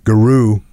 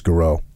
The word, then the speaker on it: Giroux: Garou opened the message. That's one of the other guys, Garou. I hope I'm pronouncing that right. G-I-R-O-U-X, Garou, opened the message.